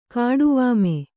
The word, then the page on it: ಕಾಡುವಾಮೆ: kāḍu vāme